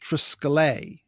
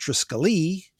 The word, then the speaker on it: triskele: In a classroom for ancient Greek, he says that it would be pronounced triskele, emphasis on the last syllable. But in modern and Byzantine Greek, it would be pronounced triskele. And triskele is typical of how it's usually translated into English, as just as we pronounce Pericles and Socrates.